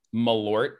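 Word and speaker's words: Malört: How do you say it? – Malort.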